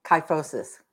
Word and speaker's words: kyphosis: Four exercises for, how do you pronounce that? Kyphosis? – Kyphosis. – Wow, I got lucky.